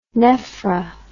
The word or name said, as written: nephr